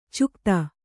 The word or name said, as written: ಚುಕ್ತಾ